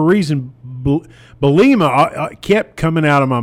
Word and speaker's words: Bielema: I love Coach Bielema, now that I know how to say it.